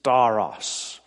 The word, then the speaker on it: stauros: The word cross in the Greek is staros. Staros. S-T-A-U-R-O-S, we'd spell it.